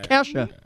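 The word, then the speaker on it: Ke$ha: How do you pronounce a dollar sign? I don't. – Yeah, it's Kesha. Kesha.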